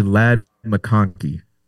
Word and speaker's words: Ladd McConkey: It's going to be Ladd McConkey, if I said his name right.